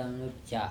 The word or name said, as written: Nuca